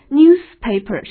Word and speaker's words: newspaper: 那么构成复合词 newspaper，注意发音，newspaper 是清辅音，newspaper 是报纸。